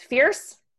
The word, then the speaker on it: Feairs: It's spelled like F-E-A-I-R-S is my last name. I like to say it's fierce.